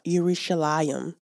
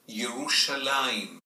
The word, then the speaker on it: Yerushalayim: Now, in Hebrew, the word Jerusalem is pronounced Yerushalayim.